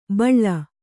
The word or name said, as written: ಬಾಳೆ